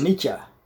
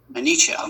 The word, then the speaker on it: Anicca: Is it Anicca or is it Anicca? I heard it pronounced two different ways. So how do we? – You referring to impermanence or not self? – Yeah. No, impermanence. – Is Anicca.